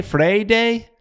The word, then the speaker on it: Freire: Freire? – Yeah. Like Friday, but with an A, Freire? Instead of Friday, Freire? – Freire.